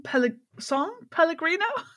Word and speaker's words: Pellegrino: I have had a nice little song Pellegrino. Is that how you say it? – That's how you say it.